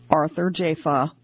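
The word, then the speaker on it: Arthur Jafa: The artist's name is properly pronounced Arthur Jaffa.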